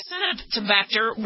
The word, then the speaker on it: Acinetobacter: I know, I used to mispronounce it as Actinobacter, but that's not how to pronounce it. It's Acinetobacter. Then they were found, the Pseudomonas and the Acinetobacter were found on many of the stethoscopes, even though it was in small quantities.